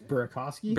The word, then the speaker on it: Burakovsky: It's Burakovsky.